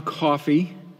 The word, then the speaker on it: Coffey: There's a prisoner named John Coffey, pronounced like the beverage, but spelled differently.